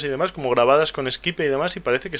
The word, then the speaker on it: Skype: Ya lo sé, pero es que como cuando lo conoces por escrito sin querer tiendes a castellanizar la pronunciación, sí. – Entonces dices pues Skype. Pero es Skype en todo el planeta, yo diría.